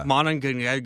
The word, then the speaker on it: Monongahela: Monongahela. I'm not pronouncing it correctly. And the Ohio River. – Yeah. Monongahela.